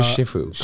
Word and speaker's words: shifu: Shifu. That's how you say it. Because the Chinese language has four ways of saying each category. Fu, fu, fu, fu. You know, it's like there's four pronunciations of each.